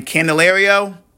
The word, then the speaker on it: Candelario: And then Candelario, I finally got that name right.